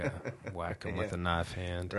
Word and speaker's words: Wacom: I think it's a Wacom. I think that's supposed to be the industry true pronunciation of the brand.